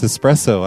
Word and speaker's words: espresso: It's espresso. I've always said espresso for some reason.